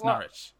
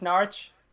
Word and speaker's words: Norwich: It's Norwich. – It's Norwich.